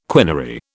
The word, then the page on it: quinary: British English